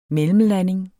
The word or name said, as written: mellemlanding